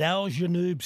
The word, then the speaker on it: Al Janoub: That's very accommodating at Al Janoub Stadium, Al Wakra. Did I say that right? – Oh, Simon's Hilly? Gone. – He's gone. Oh, I knew he was busy. He had to go to another interview, Roach. Yeah. And I just think I could faintly hear through my really bad working ears that he said, spot on, Kimbo.